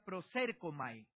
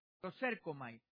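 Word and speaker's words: prosérkomai (προσέρχομαι): La palabra acerquémonos viene de una palabra griega que se pronuncia prosérkomai, prosérkomai.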